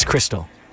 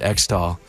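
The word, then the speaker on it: Xtal: However, I've been told by a very helpful listener, it's Crystal. Like Xmas for Christmas. Never thought of it like that. So thank you very much. I will probably keep pronouncing it X-Doll, but now I know how it's supposed to be pronounced.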